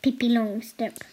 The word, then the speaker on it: Pippi Långstrump: And how do you say that in Swedish? – Pippi Långstrump.